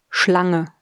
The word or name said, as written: Schlange